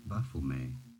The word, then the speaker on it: Baphomet: The name was first used by the French. So it is a French name originally. In French, it is said as Baphomet.